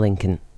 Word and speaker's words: Lincoln: Lincoln, Nachname, zum Beispiel Abraham Lincoln. Entgegen der landläufigen Meinung wird das zweite L nicht ausgesprochen. Lincoln, wie in Linkin Park.